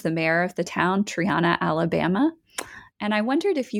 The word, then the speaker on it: Triana: It took me a while to learn how to say it, Triana, Alabama.